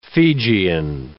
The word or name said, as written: Fijian